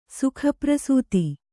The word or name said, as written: ಸುಖಪ್ರಸೂತಿ